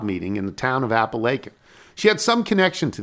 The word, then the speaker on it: Apalachin: Today, as we are driving down 88 from Albany past Cooperstown, and as we are listening to the podcast, and you were talking about Apalachin, New York, which you did say incorrectly. We're actually headed to Apalachin, where my parents now live. It is pronounced Apalachin.